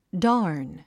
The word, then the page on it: darn: dάːrn; ダーン